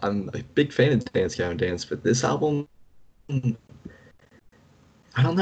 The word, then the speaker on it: Dance Gavin Dance: What uh, did I just say that right? I said Dance Davin Dance. I think. Did you? I think so. Hmm, I knew what you wow. meant. Dance Gavin Dance.